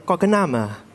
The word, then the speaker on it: Quoc Nam: En fait, mon prénom entier prononcé en français, c'est Quoc Nam. Prononcé en vietnamien, Quoc Nam.